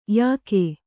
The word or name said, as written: ಯೋಗಿ